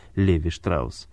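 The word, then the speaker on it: Levi Strauss: Некоторые произносят ее как Леви Штраус.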